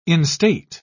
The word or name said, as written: instate